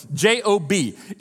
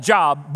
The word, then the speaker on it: Job: The oldest book in the Old Testament, theologians and historians tell us, is the book of Job, J O B. It's spelled like Job, but pronounced Job.